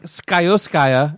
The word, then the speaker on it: Scioscia: And then the Angels manager Mike Scioscia, I believe that's how it's pronounced. – I think you got it. Yep.